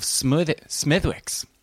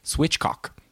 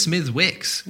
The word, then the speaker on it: Smithwick's: Okay, so Dan, he says, just wanted a message in regards to the pronunciation of Smithwick's on the show. I have always pronounced it with a silent W.